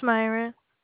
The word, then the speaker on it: Myra: My name is Myra.